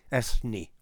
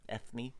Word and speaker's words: Ethne: Ethne. – Ethne. – E-T-H-N-E. – Right. – Beautiful name. – You pronounce the th, apparently.